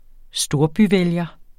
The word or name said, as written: storbyvælger